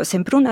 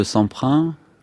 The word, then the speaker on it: Semprún: Parce que vous diriez que durant la transition démocratique, le nom de Semprún, pardonnez-moi, je le prononcerai jusqu'à la fin à la française, est un nom qui est présent dans la tête, dans les têtes des Espagnols.